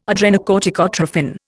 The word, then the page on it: adrenocorticotrophin: Indian